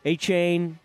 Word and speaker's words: Achane: The only guy they're really losing that was a really nice running back slash receiver for them is that, how'd you say his name? A-Chain, you know, A-C-H-A-N-E.